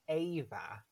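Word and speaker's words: Éabha: EA is pronounced A. It's Ava. But it's the Irish version of Eva.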